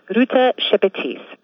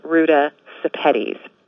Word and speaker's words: Ruta Sepetys: And an easy way to remember how to pronounce my last name is that it rhymes with Spaghetti's. So if you think Ruta Spaghetti's, you can pronounce Ruta Sepetys.